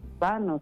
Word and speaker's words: Los Baños: Los Baños es, bueno, más que nosotros lo pronunciamos con la ñ, pero es Baños, porque no, la ñ no se pronuncia aquí.